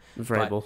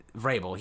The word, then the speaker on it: Vrabel: Vrabel, I don't know how to say his last name. – Vrabel. – But, I mean, Vrabel.